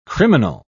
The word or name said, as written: criminal